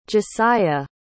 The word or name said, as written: Jesiah